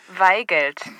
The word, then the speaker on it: Waigelt: W-A-I-G-E-L-T. – Weigelt. Also, es tut mir ausgesprochen leid, Herr Weigelt.